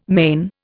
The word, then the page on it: men: Indian